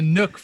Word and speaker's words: NUC: It's a NUC from what I've been told. – You're talking about the NUC, the Intel NUC, right? I always called it a NUC. – So it rhymes with Tom NUC.